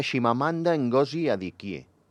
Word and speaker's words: Chimamanda Ngozi Adichie: Eh, parlem de Shimamanda Ngozi Adichie Val? – Ai, que bé ho has pronunciat.